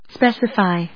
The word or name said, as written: specify